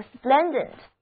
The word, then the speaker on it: splendid: 今天的最后一个词根是 SPLENG，它的意思是发光，在它的后面加上 ENT 这个形容词后缀，就得到了 splendid。 Splendid 这个形容词，它的意思是辉煌的、光彩的、显著的。第一个字母 E 读作 A，而第二个字母 E 要读作 A。